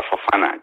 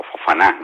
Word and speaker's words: Fofana: Yo creo que va a sacar de titular a Fofana, o Fofaná, ¿no? Por ser preciso en la pronunciación, que es un futbolista que al entrenador, por lo que dice, le ha encantado, o sea, le entusiasma.